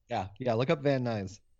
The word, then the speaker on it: Van Nuys: Van Nuys.